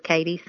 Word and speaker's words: Katie: Very often when people see my first name written, they add an H to it and call me Kathy. And if they do pronounce it as Katie, some people often follow up with this horrendous old song that begins, "K-K-K-Katie, down by the cow shed."